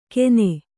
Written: ಕೆನೆ

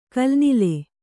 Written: ಕಲುನೆಲ

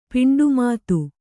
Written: ಪಿಂಡುಮಾತು

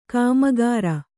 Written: ಕಾಮಕರ

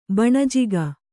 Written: ಬಣಜಿಗ

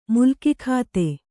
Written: ಮುಲ್ಕಿಖಾತೆ

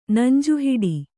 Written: ನಂಜುಹಿಡಿ

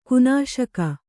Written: ಕೊನೆಸಾಗು

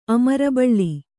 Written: ಅಮರಬಳ್ಳಿ

